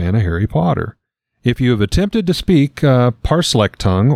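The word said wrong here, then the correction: Parseltongue: Parsel tongue, if you're going to say it right.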